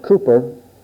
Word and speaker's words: Cowper: William Cowper, or the English pronunciation is Cooper, made this statement.